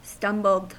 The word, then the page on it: stumbled: American English